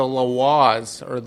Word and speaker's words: Lwa: But the Lwas, it's L-W-A. L-L-W-A is how it's spelled.